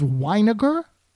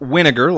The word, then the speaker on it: Weiniger: You didn't know it was Weiniger? – I thought Winiger, like vinegar.